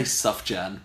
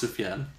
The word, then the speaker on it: Sufjan: I say Sufjan, but I don't think that's right. – I don't think that you say the J. No hard J.